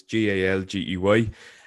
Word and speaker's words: Galgi: It's just Connor Galgi, spelled with one N, and Galgi is Galgey.